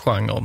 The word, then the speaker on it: genre: Det stavas genre, men det uttalas genre.